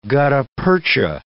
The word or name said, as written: gutta-percha